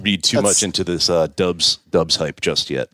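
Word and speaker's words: Doubs: And Romeo Dubs, I wish it was Dubs, D-O-U-B-S. I guess that's Dubs.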